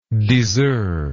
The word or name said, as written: diseur